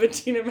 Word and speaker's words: Idina: Like, even if you're not familiar with Idina Menzel, you can look and... read the name and see that it's not Adele Dazeem. – It's Idina. Like, it's not, like, a hard name to pronounce.